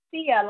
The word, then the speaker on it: Ceah: It is Sia, like see you later. It is spelled C-E-A-H as in Harry.